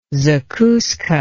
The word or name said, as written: zakuska